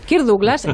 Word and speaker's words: Kirk Douglas: Bueno, bueno, que sepáis que Kirk Douglas, lo he dicho bien, ¿no? Kirk Douglas y Michael Douglas, sí. Kirk Douglas, vale.